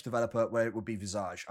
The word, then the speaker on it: Visage: I'm saying it's Visage. Because in English, a visage is when you see like an apparition or something.